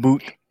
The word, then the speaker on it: But: I have Daniel Butt going there. – Daniel Boot. – Boot. – Daniel Boot. Is it spelled like butt? – It's B-U-T, but it's boot.